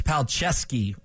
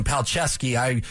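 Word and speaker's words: Palczewski: Yeah, by the way, we're going to go with Alex Palcheski on that tackle for the Broncos. My fault on getting his name wrong. Palcheski.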